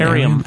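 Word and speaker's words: Airiam: And what's Arium? Is that how you say it? – Arium. – Arium.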